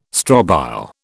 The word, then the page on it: strobile: British English